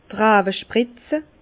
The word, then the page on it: d' Rawe spritze: Bas-Rhin; Herrlisheim